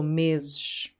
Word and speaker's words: meses: So, first S is like a Z, last S is like a sh. So, meses. Okay?